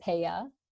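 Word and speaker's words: PEIA: So my favorite slowdown song, the artist is P-E-I-A. I believe it's pronounced Paya, or in Pittsburgh, P-A-A.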